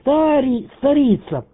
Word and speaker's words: Старица: А правильно я делаю ударение? Старица. Старица. На второй букве. На втором слоге. Старица. Или может быть, старица. Вот. Вот. Как там правильно, на приеме? – Да, второй вариант правильный. Старица. Не старица, а старица. Получается, ударение на первую букву А идет на приеме.